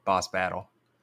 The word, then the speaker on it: boss battle: And how'd the bass bottle end up? – The what? – Bass bottle, or if you're saying it correctly, boss battle.